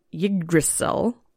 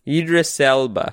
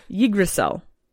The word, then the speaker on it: Yggdrasil: So the cosmos, speaking of, were supported by a huge ash tree called Yggdrasil. – Yggdrasil. – You probably know how to say it. – Idris Elba. – No. – Idris Elba? – Not Idris Elba. No. Yggdrasil.